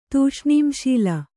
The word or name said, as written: ತೂಷ್ಣೀಂಶೀಲ